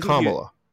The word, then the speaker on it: Kamala: No, I think Kamala.